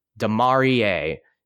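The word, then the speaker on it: Demarea: And if you're listening to this, here's how you pronounce it. Demarie. Demar-E-A. Like EA Sports, it's in the game.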